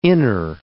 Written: inner

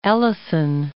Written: Ellison

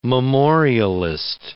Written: memorialist

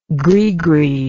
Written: greegree

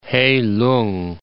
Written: Hei-lung

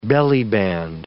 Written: bellyband

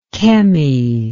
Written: Kemi

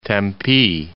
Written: Tempe

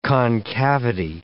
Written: concavity

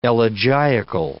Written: elegiacal

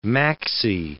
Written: maxi

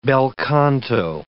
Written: bel canto

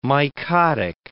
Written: mycotic

